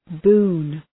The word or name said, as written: boon